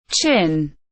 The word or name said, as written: chin